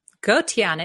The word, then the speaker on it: Gotian: Gotian.